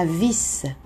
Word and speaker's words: vis: La vis, V-I-S, et ici, notez que le S final se prononce.